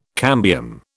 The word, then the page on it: cambium: British English